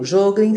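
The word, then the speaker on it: Sjögren: Jogren se escreve S-J-O-G-R-E-N de navio. G de gato, R de rua, E de escada, N de navio, ok?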